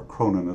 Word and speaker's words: Cronon: My name is William, middle initial J, Cronon, or Cronin is the way we pronounce it, but the spelling is C-R-O-N-O-N.